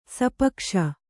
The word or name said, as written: ಸಾಪೇಕ್ಷೆ